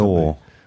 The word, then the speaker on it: gnaw: There's a verb that people won't know, to gnaw on something. – Gnaw. – Well, it's G-N-A-W. – Yeah, you don't pronounce the G. – And it's pronounced gnaw.